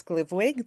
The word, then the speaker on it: Glivwig: And all of those details can be found on our website, which is GLVWG, which we pronounce Glivwig. That's our common name for the group. We call it Glivwig, and we're all Glivwiggers.